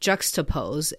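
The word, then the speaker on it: Juxtapoz: and then started a magazine called Juxtapose. It's spelled J-U-X-T-A-P-O-Z. I'm Canadian, I say that.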